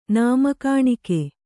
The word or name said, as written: ನಾಮಕಾಣಿಕೆ